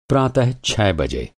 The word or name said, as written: प्रातः